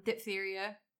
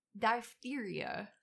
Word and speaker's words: diphtheria: I broke these down individually because DTaP is just one shot, but it's diphtheria. I'm sorry, I'm saying that wrong. – Diphtheria, tetanus. – It's diphtheria. – Oh, really? – Yeah. – I've been saying that wrong my entire adult life.